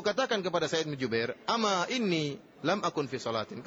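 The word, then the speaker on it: ama (أما): Jadi, jangan salah baca, jangan dibaca amma, sebagian ikhwan, sebagian tullab membacanya amma inni lam akun fi salatin, salah ya. Bacanya ama inni.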